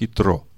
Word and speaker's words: Итро: На иврите имя Иофор здесь написано четырьмя буквами. Е, Т, Р, Вав. И читается как Итро.